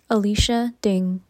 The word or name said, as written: Alicia Ding